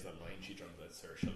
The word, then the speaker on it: Saoirse: She pronounces it Saoirse because, I mean, that's fine. But it comes from the Irish word Saoir, which is freedom. So Saoirse is how it should be.